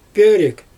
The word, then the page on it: pöörük: Xyzyl